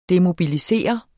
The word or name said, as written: DEMOBILISEREDE